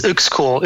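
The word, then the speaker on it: Uexküll: It's Uxkull. Uxkull, I guess. – Uxkull.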